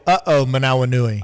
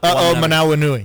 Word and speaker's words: Manawanui: The funnest name is Michael Holman Aminui. – Oh, you almost got it right. – I could never pronounce it. – Did I say it wrong? – It's Michael Manawanui. – Oh, okay. Aminui. – He's so funny. – Aminui. – I'm dead serious. Michael Manawanui. – I knew his nickname was Uh-oh. – Yeah. That's how you pronounce it. Uh-oh, Manawanui. – Uh-oh, Manawanui. Okay.